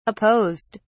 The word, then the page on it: apposed: British English